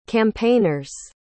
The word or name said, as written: campaigners